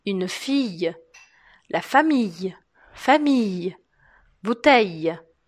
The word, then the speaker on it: bouteille: Muitas vezes as pessoas acham que tem que falar bouteille pronunciando o L marcado. Mas não é assim. Eu sempre indico que você tente fazer um I comprido, ou ainda que você interrompa um som no meio.